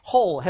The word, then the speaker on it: whole: 자, 아무튼 발음은요. whole 해가지고 W는 의미가 없습니다. 여기서는요. whole.